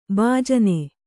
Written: ಭೂಜಾನಿ

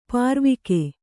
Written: ಪಾರ್ವಿಕೆ

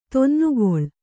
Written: ತೊನ್ನುಗೊಳ್

